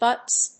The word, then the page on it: butts: bʌts